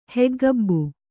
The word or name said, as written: ಹೆಗ್ಗಬ್ಬು